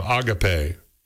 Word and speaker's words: Agape: If you want a better tasting First Christian energy drink, he recommends Preachin' Peach from Agape, or Agape as some people pronounce it.